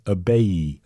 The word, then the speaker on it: Ibeyi: known as Abeyi. That's spelled I-B-E-Y-I, Abeyi.